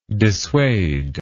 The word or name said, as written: dissuader